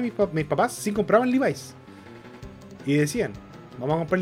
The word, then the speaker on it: Levi's: bueno, yo, yo por Shingeki no caché que los pantalones Levi se pronuncian Levi's.